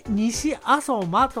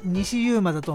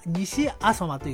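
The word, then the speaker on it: 西遊馬: 西に遊ぶに馬と書いて西阿蘇馬と読むんですが、なかなか呼びづらいですね。てっきり私西ユーマだと思っていたんですが、西阿蘇馬というところでございます。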